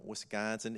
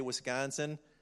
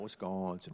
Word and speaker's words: Wisconsin: I'm a Yankee from Wisconsin. If you say Wisconsin, you get the nasal going. You got to say it right. Wisconsin, not Wisconsin, Wisconsin.